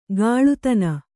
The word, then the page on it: ಕಾಳುತನ: kāḷutana